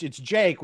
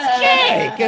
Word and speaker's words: Jake: even though it's Jake, which starts with a J. Sometimes they will call me Chubby Balding Steve Buscemi. And I'm like, it's Jake. It's like one syllable.